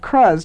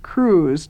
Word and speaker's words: Cruz: She always thought it was pronounced Cruz to rhyme with buzz, but just this year she found out it's pronounced Cruz to rhyme with booze.